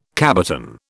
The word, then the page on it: cabotin: British English